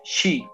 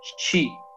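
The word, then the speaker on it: shi (四): Porque cuando ustedes dicen en japonés número 4, se pronuncia shi y la palabra muerte se pronuncia exactamente igual, chi, aunque se escriba diferente el símbolo.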